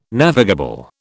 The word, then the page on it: navigable: British English